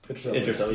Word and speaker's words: Idris Elba: Idris Elba, yes.